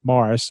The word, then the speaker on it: Maurice: Everybody pronounces it Morris, so we're going to go with Morris.